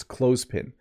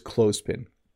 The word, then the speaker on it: clothespin: Notice this word looks difficult to pronounce, but it's not too bad. It's clothespin. So you don't have to say the th as a the sound. It's just clothespin.